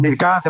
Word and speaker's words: MidCat: que es el Midcat, ¿no? No sé si lo he dicho bien. – El Midcat, el H2M, entonces, el Barmar.